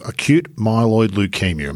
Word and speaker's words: acute myeloid leukaemia: And at that time, he'd already been through his first bout of acute myeloid leukaemia.